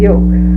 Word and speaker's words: yolk: Not the yelk. – No, not the yelk. I was going to say, now that's spelled Y-O-L-K, and I pronounce it just the same as I would a yoke of oxen.